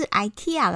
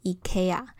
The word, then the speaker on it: IKEA: 再来，我想提醒大家，IKEA 是一间瑞典家具公司，在瑞典文当中呢，这个字念成 IKEA。所以，如果硬要说最正确的发音，应该是 IKEA，而 IKEA 呢是美国和英国人在念的，所以其实他们也不是念得很正确。